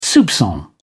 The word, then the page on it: soupcon: British English